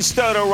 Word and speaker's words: Stoto: Ben Stoto rhymes with photo, and I are really going to do a deep dive on Atkore who's been unbelievable lately.